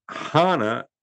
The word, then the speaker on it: Hana: Why are you saying Honda if it don't have an H? – That's the way it's pronounced.